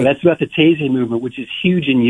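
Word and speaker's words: Taizé: And you also did a booklet on, am I saying this right, Taze? Is that how you say that? – Taze. – Yeah, okay.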